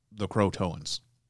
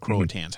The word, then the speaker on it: Croatoans: more appropriately the Croatoans. Or the Croatans, mm-hmm. however you want to pronounce it.